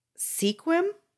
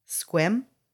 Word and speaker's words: Sequim: Take out the E and pronounce it like you would without the E. – Squim? – Good job. You got it.